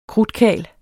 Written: krudtkarl

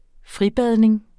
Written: fribadning

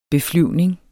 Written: beflyvning

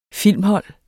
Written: filmhold

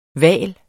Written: vals

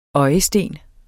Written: øjesten

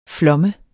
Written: flom

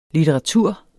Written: litteratur

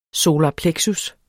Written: solar plexus

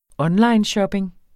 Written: onlineshopping

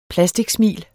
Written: plasticsmil

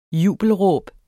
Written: jubelråb

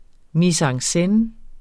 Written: scene

